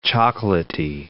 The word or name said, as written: chocolatey